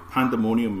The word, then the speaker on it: Pandaemonium: So, I've got Pandemonium. I can't even say it right. – Pandemonium Miniatures.